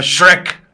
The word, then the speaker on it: Shrek: Sí, sí, La H también se pronuncia, me la pasé gritándoles todo el tiempo. La H se pronuncia, se llama Shrek.